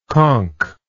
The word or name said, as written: conched